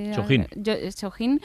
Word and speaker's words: Chojin: Chojin.